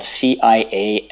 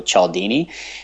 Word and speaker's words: Cialdini: Robert Cialdini, his last name is a little bit tricky. It's spelled Cialdini, so Cialdini.